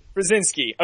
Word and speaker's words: Krasinski: And I'm pronouncing his name wrong. It's Krasniski. – Who cares? Polish people in their last name. – Right, yeah. Let's pronounce this for me. – No, you're good. – Krasinski. – Krasinski. Okay, very good.